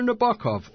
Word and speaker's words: Nabokov: They said Vladimir Nabokov or Vladimir Nabokov.